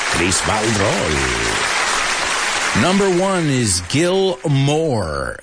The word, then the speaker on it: Moore: Gil Moore. We looked at the word Moore before, right? In our homophone section. Pues esto como apellido, M-O-O-R-E, se pronuncia al menos en inglés americano como más, right?